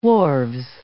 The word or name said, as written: wharves